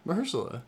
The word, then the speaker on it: Mahershala: I've never pronounced his first name correctly. – Mahershala.